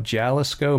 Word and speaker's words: Jalisco: But it says, our story began with an epic journey to the distant highlands of Jalisco, Mexico.